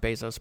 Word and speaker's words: Bezos: I should know by now it's pronounced Bezos, but I fall back to the wrong pronunciation.